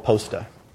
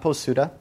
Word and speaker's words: POSTA: So our solution to that right now is called POSTA. That's short for Postgres Statistics. The U is unvoiced, POSTA, not POSUDA.